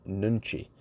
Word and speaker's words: nunchi: And that's kind of the summary of this book by Yuni Hong, The Power of Nunchi. How do you pronounce N-U-N-C-H-I?